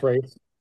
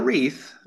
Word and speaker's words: wraith: So not Christmas wraiths. – Not a wreath, but a wraith. A wraith.